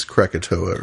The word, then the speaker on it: Krakatoa: I'm pronouncing that wrong, but it's Krakatoa or something. – That sounds right.